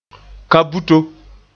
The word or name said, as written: kaa-buto